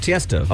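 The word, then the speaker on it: Tiësto: No, it's Tiesto.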